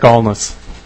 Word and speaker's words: Ahlness: Colonel Eric Alness. – Could you spell that for me? – Sure. A-H-L-N-E-S-S.